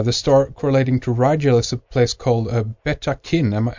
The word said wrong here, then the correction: Betatakin: Am I pronouncing that correct? – Well, it's called Betatakin. That's a Navajo name.